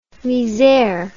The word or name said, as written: misere